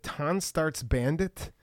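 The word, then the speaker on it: Tonstartssbandht: I believe it's pronounced Tonstarts Bandit. Tonstarts Bandht, all one word.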